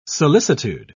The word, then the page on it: solicitude: British English